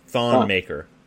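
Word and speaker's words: Thon Maker: Thawne Maker.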